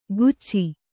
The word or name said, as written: ಕುಚ್ಚಿ